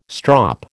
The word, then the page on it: strop: American English